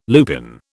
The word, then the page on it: lupin: British English